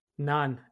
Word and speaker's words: nan: So Iranians pronounce it as nan.